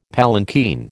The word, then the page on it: palanquin: American English